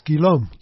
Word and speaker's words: Guillaume: It's G-U-I-L-L-A-U-M-E. – Pronounce Guillaume. – Guillaume. – Guillaume. – That's French.